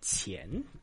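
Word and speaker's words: qian (钱): Ajá, qian, así.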